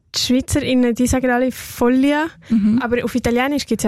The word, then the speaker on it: Follia: Mit sanften Klängen begleitet sie da euch durch den Mittwochnachmittag durch das Female Music Special, die Folia. Folia, jetzt habe ich es gerade falsch gesagt, stimmt. Oh, das habe ich gerade schwätzen. F-O-L-L-I-A schreibt man. Und Folia, hast du mir verraten, sagt man.